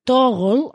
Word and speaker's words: Toggl: Tiene un contador, en este caso os voy a hablar de Toggle, que si no habéis oído hablar de ella, se escribe T-O-G-G-L, ¿vale? Toggle, así muy raro, muy como hola, pues sí, un poco así.